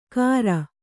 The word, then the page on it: ಕಾರ: kāra